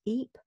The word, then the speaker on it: Ieper: In written form, it's written as Y-P-R-E-S. That is the French form, or pronounced in French as Ypres. I use the Flemish form, which is Ypres, which is written as I-E-P-E-R.